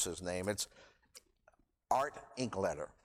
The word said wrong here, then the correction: Linkletter: Yeah, Linkletter.